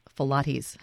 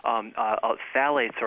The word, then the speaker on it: phthalates: And I once heard someone think that word was pronounced phylates. Not something to drink.